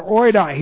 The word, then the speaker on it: oida: And oida, that's O-I-D-A, long A, oida.